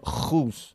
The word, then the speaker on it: whose: And be very, very careful with your pronunciation of whose. Okay? – Ha. – Ha. Imagine you're cleaning a mirror or if you're cleaning your glasses. Okay? Y no, whose.